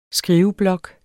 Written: skriveblok

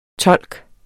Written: tolk

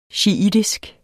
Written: shiitisk

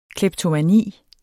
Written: kleptomani

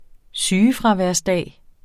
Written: sygefraværsdag

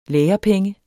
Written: lærepenge

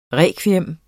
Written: rekviem

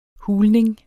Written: hulning

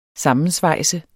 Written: SAMMENSVEJSET

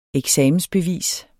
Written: eksamensbevis

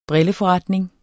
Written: brilleforretning

